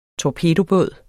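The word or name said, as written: TORPEDOBÅD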